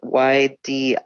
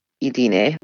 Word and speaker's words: Ydine: And my name is spelled Y-D-I-N-E. Looks like Y-Dine. Uh-huh. It's Idine.